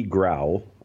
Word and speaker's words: Grau: Grau, I believe is how you pronounce it, G-R-A-U,